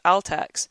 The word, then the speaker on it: Altex: and Altext, who are, or even Altex, I should pronounce it properly, who do electronics, um, a big electronics retailer with both physical stores and an online opportunity.